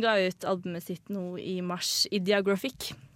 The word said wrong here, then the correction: Ideographic: Ideograph, ide, ideographic? – Ideographic.